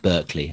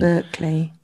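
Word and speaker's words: Berkley: Is it Barclay? – I'm not sure. Is it spelt with a B E or B? – B E. Yeah. – Yeah. So it's more. I think it's more like Berkeley.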